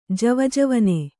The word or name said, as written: ಜವಜವನೆ